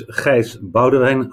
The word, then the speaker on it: Gijs Boudewijn: So my name is Gijs Boudewijn.